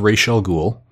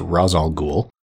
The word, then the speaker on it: Ra's al Ghul: He was cast as Ra's al Ghul, which is constantly mispronounced during the course of the film as Ra's al Ghul,